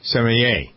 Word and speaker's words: sommelier: She is a, how do you pronounce it, sommelier? – Sommelier.